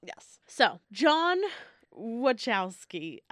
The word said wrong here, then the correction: Wachowski: No, that's not how you say it. God damn it. I wrote it out phonetically. Watch Twix.